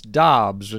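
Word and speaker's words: Doubs: But I do wonder if there's going to be a little bit of a lag period while they're getting into the Romeo Dubs experience. Or Dobbs? Is it Dobbs? Is that how we confirmed it's pronounced? – Apparently he wants Dobbs, and it's funny because we all heard Dubs pre-draft,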